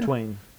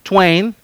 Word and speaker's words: Twain: Mark Twain, ¿cómo se dice? – Twain. – Twain.